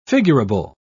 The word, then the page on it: figurable: British English